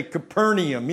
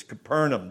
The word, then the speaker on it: Capernaum: I don't say Capernaum either. It's Capernaum. There's no E in Capernaum.